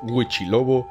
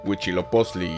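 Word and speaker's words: Huitzilopochtli: Al no poder pronunciar Huichiloposli y Tezcatlipoca, los conocían como Huichilobo y Tezcatepuca.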